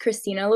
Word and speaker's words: Xtina: Yeah, so it's really actually pronounced Christina Louise, but it gets kind of confusing. I just added the X because I thought that it kind of made the name unique and added a little edge.